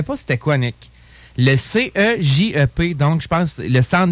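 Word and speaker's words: cégep: C'est Jeff. – Manifestement. – C'est un G.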